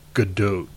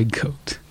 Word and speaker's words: Gadot: And her last name rhymes with float. So Gal Gadot. – Oh, I thought you were going to say goat. – No, Gadot.